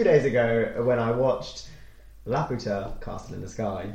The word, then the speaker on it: Laputa: You nailed it. You got the name right. – I've never said the name right. – I feel like we had a week of you being like, La Pupla.